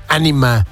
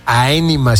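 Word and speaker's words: Ænima: Ese es el track de Tool, llamado 46 and 2, de este álbum, pues que lleva por nombre Anima o Aenima, si usted lo quiere pronunciar así. Pero bueno, hay otros que le dicen Enema, pero es el juego de palabras cochinón, como siempre ahí, Don Maynard, James Keenan, por supuesto.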